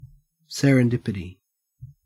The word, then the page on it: serendipity: Australian English